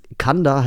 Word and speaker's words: C&A: Und Kanda heißt einfach nur C&A, also C&A, englisch ausgesprochen.